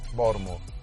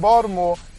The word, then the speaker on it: Baltimore: Baltimore. Baltimore. – Baltimore? – Baltimore. Baltimore. – So there's no T in there. – Look, you got to say, you pronounce the T, you're not from Baltimore.